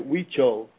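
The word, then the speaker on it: Huichol: Your work is about the soul and the Hui Chol. Am I saying that right, Hui Chol? – Perfect, yeah, Hui Chol.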